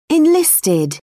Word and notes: enlisted: British English

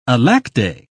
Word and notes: alackaday: British English

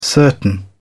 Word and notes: certain: British English

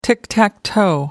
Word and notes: tick-tack-toe: British English

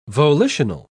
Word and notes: volitional: British English

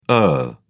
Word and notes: ur: British English

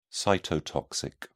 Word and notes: cytotoxic: British English

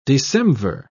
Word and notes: decemvir: British English

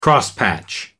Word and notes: crosspatch: American English

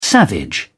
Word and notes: savage: British English

